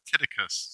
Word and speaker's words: Tychicus: Caitlin, who is an expert on these things, certainly far more than I am, tells me that first name is pronounced Tychicus, so that's what we're going to go with.